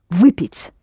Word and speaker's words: выпить: Выпить. Yes, pronounce it hard, please.